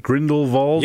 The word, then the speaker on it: Grindelwald: Did I say it right, Brett? Grindelwald? – Yes.